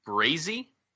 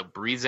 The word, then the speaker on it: Breazae: Yeah, I'm gonna assume it's Breezy. – Yep. – Or Breeze. – Spell it. – Here we go. Oh, spell it Breazae. – There you go. – So Breeze.